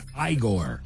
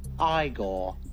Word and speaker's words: Igor: This classic flick featured a character whose boss mispronounced his name as Igor. – You must be Igor. – No, it's pronounced Igor.